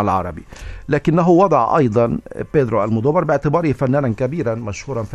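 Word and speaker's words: Almodóvar: أنا أود أن أرى كيف يرى العرب بيدرو المودوفار، ويسمونه المودوفار، وهذا بالطبع يعني النطق كما يبدو في حرف الفاء ربما ينطقونه كذا حسب شكل الكتابة، لكن في الحقيقة هو بالإسبانية ينطق المودوفار، والتي تأتي من العربية كما ذكرنا المدور.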